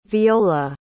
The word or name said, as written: veola